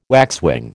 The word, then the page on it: waxwing: American English